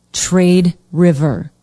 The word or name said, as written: trade river